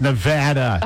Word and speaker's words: Nevada: I said Nevada. No, it's Nevada. – Oh, geez. – It's like, Nevada.